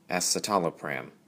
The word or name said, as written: Escitalopram